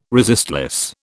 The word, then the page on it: resistless: British English